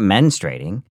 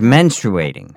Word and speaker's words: menstruating: You're not pronouncing the R-U. Menstruating. It's not menstruating. – Oh, it's menstruating.